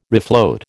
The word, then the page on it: refloat: American English